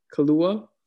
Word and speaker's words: Kaluuya: Kaluuya. – Name? Kaluuya.